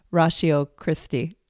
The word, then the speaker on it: Ratio Christi: Ratio Christi.